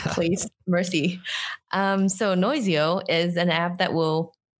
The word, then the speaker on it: Noizio: It is called uh, called Noizio.